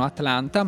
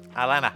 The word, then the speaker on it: Atlanta: Perché dovete sapere che gli statunitensi non pronunciano Atlanta ma... – Alana.